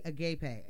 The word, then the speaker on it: agape: And then also said, I think y'all's love language may be agape, if I'm pronouncing that agape. – Agape, yep.